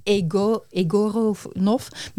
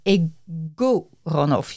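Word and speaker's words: Egorov: Wij zouden zeggen Egoronov, maar het is Egoronov. Je moet de klemtoon anders leggen.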